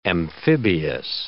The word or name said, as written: amphibious